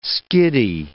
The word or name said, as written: skiddy